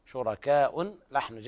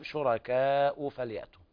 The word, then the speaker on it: شركاء: أم لهم شركاء فليأتوا، هنا الهمزة مضمومة ضمة واحدة وليس تنوين، شركاء لحن جلي خطأ، الصواب شركاء فليأتوا.